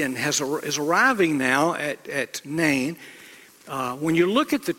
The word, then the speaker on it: Nain: We would, English letters, we would spell it N-E-I-N. In the Bible, in English letters, it's N-A-I-N, and you may call it Nain. Or if you like to separate vowel sounds from diphthongs, you may say Nain, which is probably how the Hebrews would pronounce it.